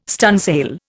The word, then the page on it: stunsail: Indian